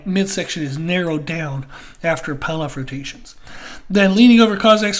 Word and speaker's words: Pallof: Then pull-off rotations, it's spelled P-A-L-L-O-F if you want to look those up on YouTube.